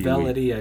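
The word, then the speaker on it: Auvelity: And A-U-V-I-L-I-T. – Avelity, I believe, Avelity is how you pronounce it.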